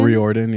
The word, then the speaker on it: Riordan: Also to, if it makes sense, especially this story was written so long ago, maybe our homeboy, Rick Riordan. How do you pronounce Riordan? – Riordan, yeah.